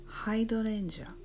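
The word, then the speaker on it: hydrangea: アジサイはハイドレンジャー、ハイドレンジャー、音節は 3つ、ちょっと発音難しいんですが、ハイドレンジャー、ハイドレンジャーです。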